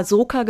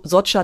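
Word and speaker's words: Soča: habe auch immer Soka gesagt am Anfang, aber ich glaube Camp Socha, also es ist das so Socha Tal. Mhm. Socha ist der Fluss, der da durch dies, dieses Tal läuft äh, im Nationalpark, im Triglav Nationalpark.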